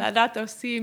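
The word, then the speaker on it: Laudato si': So, Laudato si, am I pronouncing that right? – Laudato si, mi signore.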